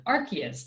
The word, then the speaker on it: Arceus: Firstly, before I dive into my thoughts about Diamond and Pearl, I just want to say this about Pokemon Legends Arceus, the official pronunciation, Arceus, that always sort of annoyed me on some level, whatever.